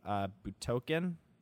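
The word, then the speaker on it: Budokai: I got to play Dragon Ball Z Extreme Budokan. – Yep. – Sure, why not? – Yeah, you're saying it right.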